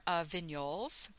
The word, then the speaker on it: Vignoles: You could try something like a Catawba wine, which is a sweet blush, or Vignoles, which is spelled V-I-G-N-O-L-E-S, the G is silent, mm-hmm.